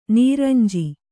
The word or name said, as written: ನೊರಂಜು